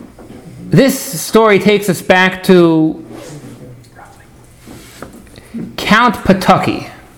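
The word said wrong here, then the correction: Potocki: Patutsky, and uh, David will correct me for any, for any of the issues pronounced Pototski. Pototski. Pototski. Yeah. Count Pototski.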